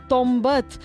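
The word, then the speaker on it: Tombet: Tombat s'escriu amb bo, Tombat. Tot i que segurament ho pronuncieu amb tombat i segurament ve de tombar, quan s'han de tombar les peces de verdura per fregir-se.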